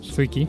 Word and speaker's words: Zwicky: Un año después, en 1933, Fritz Zwicky, Zwicky, me preguntó a Emilio cómo se pronunciaba, es que lleva una W ahí por el medio,